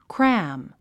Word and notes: cram: krǽm; クラム

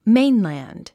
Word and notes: mainland: méinlæ`nd; メインランド

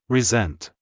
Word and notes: resent: rizént; リィゼェント

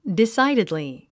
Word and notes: decidedly: disǽididli; ディサイデドリィ

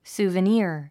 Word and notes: souvenir: sùːvəníər; スゥベニヤァ

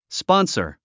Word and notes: sponsor: spɑ’nsə r; スポンサァ

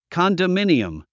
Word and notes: condominium: kὰndəmíniəm; カンダミニアム

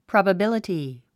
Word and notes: probability: prὰbəbíləti; プロバビラティ

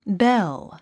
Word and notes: belle: bél; ベェゥ

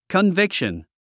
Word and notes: conviction: kənvíkʃən; カンビクション

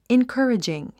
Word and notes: encouraging: inkə́ːridʒiŋ; インカーリジン

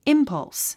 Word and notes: impulse: ímpʌls; インパァウス

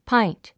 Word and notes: pint: páint; パァイント

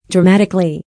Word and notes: dramatically: drəmǽtikəli; ドラマァティカリィ

